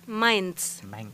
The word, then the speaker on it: Mainz: Mainz.